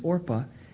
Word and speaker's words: Orpah: Incidentally, I understand that Oprah got this, her name, or her mother named her after this, but didn't pronounce it right, or didn't spell it right, or changed it purposely. But the name pronunciation here is Orpah.